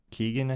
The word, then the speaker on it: Keoghan: Ke- Keoghan, I think is how you say his name.